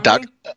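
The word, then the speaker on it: Dacre: Also returning, we have Decray Montgomery. – Docker. I think that's how you say it. – Docker, yep. Montgomery as Billy.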